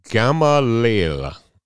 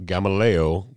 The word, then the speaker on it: Gamaliel: And he studied and he was educated under Gamaliel not Gamaleo, Gamaleo. Some